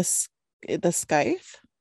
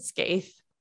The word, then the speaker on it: scythe: What do you mean, the scythe? – Yeah, I said scathe.